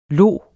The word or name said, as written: los